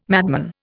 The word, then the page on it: madman: Indian